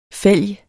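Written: fælg

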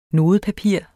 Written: nodepapir

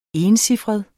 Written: encifret

